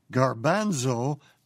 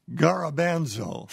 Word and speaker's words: garbanzo: She writes, "Dear Dr. Zorba, the word for chickpea is pronounced garbanzo, not garabanzo."